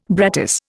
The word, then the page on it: brattice: Indian